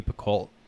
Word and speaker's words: Picoult: It's a Jodi Picoult.